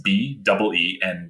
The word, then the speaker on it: Beentjes: That's Tim Beentjes. The last name is spelt kind of tricky. It's Beentjes.